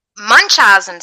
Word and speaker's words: Munchausen: Anyways, she accused her of having Munchausen syndrome.